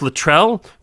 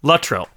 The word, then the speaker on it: Luttrell: I always thought of it as Luttrell with the accent on the second syllable, but I was listening to the commentary yesterday, and Rachel Luttrell and Carson Beckett, blanking on his name, Paul McGillion, there it is, we're doing the commentary, and she pronounced her name Luttrell, so I'm going to go with Luttrell.